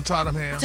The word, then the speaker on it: Tottenham: He'll perform at Tottenham Hotspur. Is that how you say it? – Uh-huh. On Tottenham.